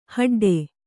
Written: ಹೆಡ್ಡಿ

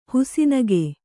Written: ಹುಸಿನಗೆ

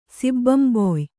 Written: ಸಿಬ್ಬಂಬೊಯ್

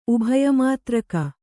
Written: ಉಭಯಮಾತ್ರಕ

